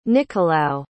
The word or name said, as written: Nicolao